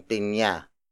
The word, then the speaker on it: pinya: For example, sampung pinya, sampung pinya, sampung pinya.